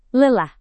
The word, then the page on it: lelah: indonesian